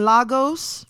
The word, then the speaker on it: Lagos: I'm a vibe because made in Lagos, made in, and I don't want to hear no made in Lagos. It's called Lagos. – Mm-hmm. – L-A-Y-G-O-S mm-hmm. is how you pronounce it, but mm-hmm. it's spelled L-A-G-O-S.